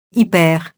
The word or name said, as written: hyper-